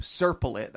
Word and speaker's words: Serpollet: That's how he pronounces it. Not me. I looked it up. Serpollet. That's a French pronunciation.